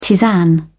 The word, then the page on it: tisane: British English